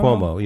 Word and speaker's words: Cuomo: Is that how you pronounce it? Cuomo? – Cuomo, yeah. Okay.